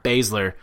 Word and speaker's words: Baszler: Ember Moon versus Baszler. – Shayna Baszler. – We're really good at pronouncing names. It's Baszler.